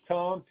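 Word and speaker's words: Cervino: First name is Tom, T-O-M. The last name is spelled C-E-R-V as in Victor, I-N-O.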